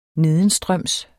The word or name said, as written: nedenstrøms